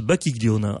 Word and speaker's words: Bacchiglione: Donc j'ai bûché avant de venir parce que ça s'écrit Bacchiglione et ça se prononce Bacchiglione.